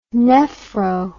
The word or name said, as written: nephro